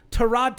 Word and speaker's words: Tyrod: And real quick, because I didn't get to finish my point on quarterbacks, Tyrod Taylor, and I made sure I said his name right, Tyrod Taylor. It's Tyrod.